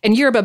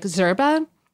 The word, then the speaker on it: yerba: And yerba, by the way, is a super American pronunciation. In Spanish, it's closer to like zerba. And in Portuguese, it's closer to erva.